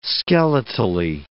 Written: skeletally

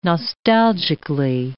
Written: nostalgically

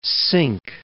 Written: Cinque Ports

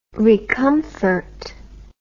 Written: recomfort

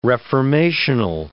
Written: reformational

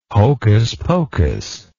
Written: hocus pocus

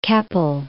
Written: Keppel

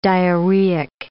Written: diarrheic

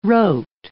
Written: wrote

